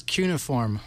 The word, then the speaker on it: cuneiform: And look, in this dictionary, cuneiform, three syllables, cuneiform, all three are acceptable.